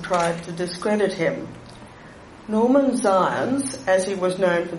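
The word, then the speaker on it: Zając: His parents were Jewish, and Henry's surname was originally spelled Z-A-J-A-C, Polish for hair. It is pronounced in Polish as Zions.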